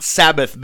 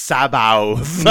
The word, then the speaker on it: Sabaoth: I know it's not Sabbath, but it's Sabaoth. I don't know how do you pronounce that? – Sabaoth. – No. – That's how you say it.